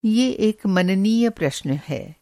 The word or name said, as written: मननीय